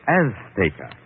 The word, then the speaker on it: Azteca: She gave the Z a TH sound. That's Castilian Spanish. Mexican or any Spanish American would say Azteca.